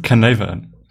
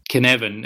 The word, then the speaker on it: Cynefin: Canevan. – Canevan. And obviously you would spell that C-Y-N-E-F-I-N. – Right, because English. – Because Welsh, actually, yeah.